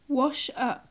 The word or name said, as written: wash up